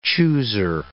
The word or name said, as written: chooser